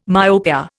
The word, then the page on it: myopia: Indian